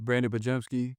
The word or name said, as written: Brandin Podziemski